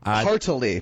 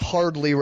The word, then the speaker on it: heartily: Heartily, by the way, that's a three-syllable word, not hardly recommend it.